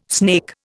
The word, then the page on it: snake: Indian